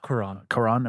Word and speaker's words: Cuarón: I'm a big fan of Alfonso Cuarón's. – Cuarón. – Am I saying that wrong? – There's no I.